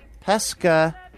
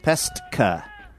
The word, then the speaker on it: Pestka: Dorothy Pratt of Thomaston, Connecticut, Karen Hendricks of Seattle, Washington, Lori Hope of Everett, Washington, local Camaro Kev pronunciation, Everett, Washington, Cindy Pestka, not Pesca, Pestka, with an S-T-K-A of Seattle, Washington, and Chris Aquino of Portland, Oregon.